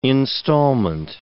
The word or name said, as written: instalment